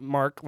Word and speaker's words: Mark: A government would give a privateer a letter of marque, and it's spelled M-A-R-Q-U-E, not Mark like my name, but it's pronounced the same, which meant they could legally be a pirate for their government.